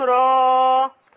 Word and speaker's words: را: ألف لام را. – لا أقول ألف لام را. – را را را بس مفيش همزة.